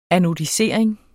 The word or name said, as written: anodisering